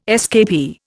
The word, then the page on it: escapee: Indian